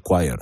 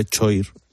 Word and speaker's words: choir: Estoy rodeado por el Black Light Gospel Choir. Se dice choir, aunque se escribe choir, se dice choir, tú lo sabías, ¿no?